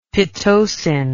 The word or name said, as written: pitocin